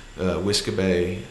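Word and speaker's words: uisge beatha: Whiskabay, I think, is how you pronounce it. That sounds about right.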